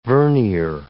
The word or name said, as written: vernier